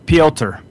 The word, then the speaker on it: Piotr: It's Piotr. – Piotr. – It's P-I-O-T-R, and you pronounce every letter, so Piotr.